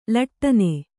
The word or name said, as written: ಲಾಟನ